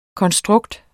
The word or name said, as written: konstrukt